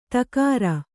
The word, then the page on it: ತಕಾರ: takāra